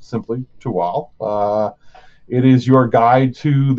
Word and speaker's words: Tehuatl: It is spelled T-E-H-U-A-T-L. So it's naturally, most people prize Tehuatl. It's really Tual is the way you pronounce it.